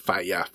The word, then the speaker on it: Faiya: One of the things with the Faiya name, I found out that the Faiya name, which is spelled F-A-I-Y-A, obviously playing off fire, but fire doesn't sounds nerdy when I say fire after saying Faiya. It's like Faiya, you know, obviously it's talking about fire.